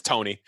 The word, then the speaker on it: Tawny: But it was like Tawny, like T-A-W-N-Y. It's Tony.